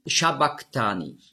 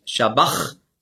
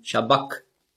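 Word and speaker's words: sabachthani: Und das CH im Griechischen, das ist der ein Buchstabe, das Chi, das hat man ausgesprochen so wie ein C hinten im Gaumen. K. Also nicht Shabach, sondern Shabak.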